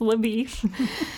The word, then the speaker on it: LaBeouf: Marshal Rooster Cogburn and Texas Ranger LaBeouf, which is spelled LaBeouf, but is pronounced LaBeef, embarks on a journey to find Tom Chaney.